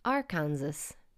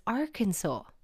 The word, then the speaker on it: Arkansas: To me, if I'd never seen that written down, I would think there's a saw in there somewhere. Saw. S A W. No, it is spelt A or K A N S A S. So you would assume because there is another American state called Kansas that it is Arkansas. No, but it's Arkansas.